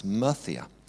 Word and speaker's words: Murcia: 4 years ago, I am in the city of Murcia, or as it's locally pronounced, Murcia.